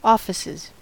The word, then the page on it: offices: American English